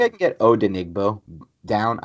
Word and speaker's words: Odenigbo: Odenigbo. That's it. When you just break it down to three syllables, it makes it so much easier.